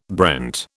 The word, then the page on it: brent: British English